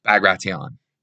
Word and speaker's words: Bagration: This is Operation Bagration. It's not Bagration. I've heard this a few times. Bagration is how it's spelled. It's Bagration. Anyway, what is this Operation Bagration?